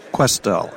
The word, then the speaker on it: Questel: Ellis is going to come off as Quinstel. Am I saying that right? – Chandler Questel.